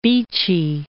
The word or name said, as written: Beachy Head